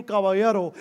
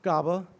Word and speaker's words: Caballero: In Spanish, the double L makes a yeah sound. The ER makes a the sound. Caballero,